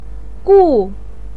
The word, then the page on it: gu: 4